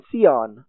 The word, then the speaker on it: scion: The grafting process, the term for it in English, it's spelled S-C-I-O-N, Sion. You can also render that, Sion, Zion.